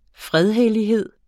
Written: fredhellighed

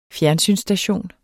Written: fjernsynsstation

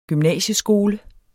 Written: gymnasieskole

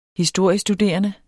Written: historiestuderende

